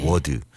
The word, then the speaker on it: word: word. 근데 이게 처음에 이 W 이 소리는요. 입술을 한번 동그랗게 해서 모았다가 풀면서 워 이렇게 됩니다.